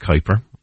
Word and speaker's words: Kuiper: And it's pronounced Kuiper, although spelled K-U-I-P-E-R.